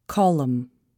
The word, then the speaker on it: column: The letter N is not pronounced following M at the end of a word. Autumn, column.